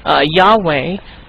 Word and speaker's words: Yahweh: Now, the word we were talking about, Yahweh, Yahweh, if you notice, the real word is to be spoken as a breath. Like you go, that's his name. You cannot pronounce Y-H-W-H. So what people have done was they put the A and the E in there. Amen. So it's so to say Yahweh. But when you say his name, it's like a breath.